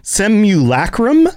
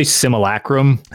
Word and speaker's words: simulacrum: So the section that we get in Elder Scrolls Online is based around this idea of the Seven Secrets and the Simulacrum, I think is the pronunciation. – Yeah, I always say Simulacrum. – Simulacrum. – You kind of don't pronounce the U is what Simulacrum.